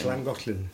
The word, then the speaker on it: Llangollen: Llangollen.